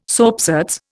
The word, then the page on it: soapsuds: Indian